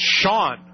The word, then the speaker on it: Chan: Her name is spelled C-H-A-N. But it's pronounced Sean. And I think it's Sean Matthews or Sean Mason or something like that.